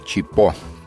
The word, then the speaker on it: Chipo: «Chipo» – ударение на последнем слове.